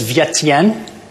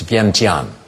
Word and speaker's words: Vientiane: What is Vientiane? – No. Kevin or Sarah? You mispronounced it too badly. It's Vientiane.